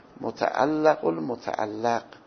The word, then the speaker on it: متعلق المتعلق: هر دوش مفتوح تلفظش، متعلق المتعلق.